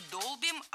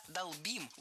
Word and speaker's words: долбим: Это удивительные красоты, а мы сидим и 4 года долбим этот дискриминант, ты понимаешь? – Не долбим, а долбим. Ударение в этом глаголе всегда стоит за корнем. Долбишь, долбит, долбим, долбят. Во всех формах ударяем окончание.